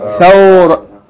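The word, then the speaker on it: Thawra (ثورة): Thawratun. Thawratun. Thawratun. If we stop on this word, how do we pronounce it? Thawra. Huh? I hope I'm not hearing somebody saying thawrat.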